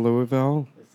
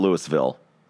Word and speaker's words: Louisville: No, it's not Louisville. – Nobody Louisville. – Knows Louis. – One Louisville. Syllable. Louisville. – No, it's two. – Sound like you gargled. – Yeah, you have to say it like you got balls in your mouth.